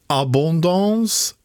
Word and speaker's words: Abondance: Än så länge så gäller ostarna Abonda med reservation för det franska uttalet Abondance, Beaufort och Comté. – Lät jättebra.